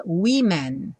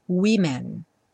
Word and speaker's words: women: Também a última sílaba é que muda, ao invés de woman com a gente escreve com e, mas curiosamente a pronúncia muda no começo e a gente fala women no plural. Então, uma mulher, a woman, mulheres, women.